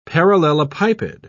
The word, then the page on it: parallelepiped: British English